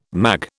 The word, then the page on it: mag: British English